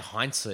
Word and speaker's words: Heinze: How do you say his surname? I'm sure that's wrong. – You can say Heinze if you want, but they used to say Gabriel Inse. – Yeah, I said Heinze.